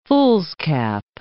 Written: foolscap

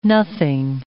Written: nothing

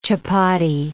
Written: chapati

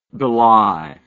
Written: bely

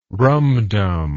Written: rumdum

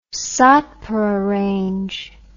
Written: Satpura Range

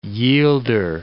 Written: yielder

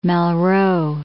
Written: Malraux

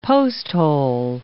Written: posthole

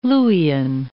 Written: Luwian

